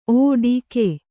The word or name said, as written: ಓಡಿಕೆ